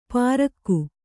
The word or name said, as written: ಪ್ರಾಕ್ಕು